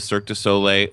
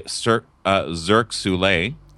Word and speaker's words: Cirque du Soleil: I love that Nene pronounces Cirque du Soleil, Cirque Soleil.